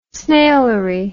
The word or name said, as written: snailery